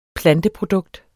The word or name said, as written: planteprodukt